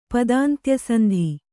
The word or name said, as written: ಪದಾಂತ್ಯಸಂಧಿ